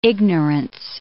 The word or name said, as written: ignorance